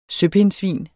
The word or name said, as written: søpindsvin